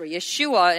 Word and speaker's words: Yeshua: But here is Yeshua, or Jesus, or Yeshua in his original pronunciation, saying destroy this temple.